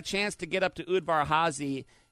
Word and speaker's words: Udvar-Hazy: Udvar-Hazy.